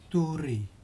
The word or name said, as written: turi